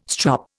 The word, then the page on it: strop: Indian